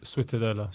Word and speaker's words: Ciutadella: I hope I'm saying it right. Ciutadella? – Ciutadella. – Ciutadella. – Ciutadella. – That's Italian, bro.